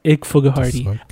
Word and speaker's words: Ikfghrddy: IKFGHRDDY, and it's pronounced Ik Fugahardy.